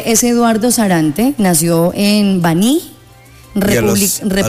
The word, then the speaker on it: Baní: Nació en Baní, con el acento en la I, República Dominicana.